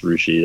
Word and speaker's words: Russia: They're out of Rushi, Ohio, spelled like Russia, but it's pronounced Rushi.